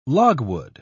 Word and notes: logwood: British English

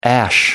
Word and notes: ash: American English

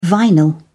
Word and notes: vinyl: British English

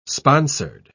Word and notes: sponsored: British English

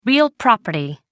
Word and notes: real property: American English

